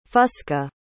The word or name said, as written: fusca